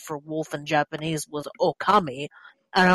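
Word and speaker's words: Ōkami: And my husband looked at the kanji and he's like, yeah, the kanji says spirit god, but it's pronounced okami.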